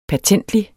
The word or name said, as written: pertentlig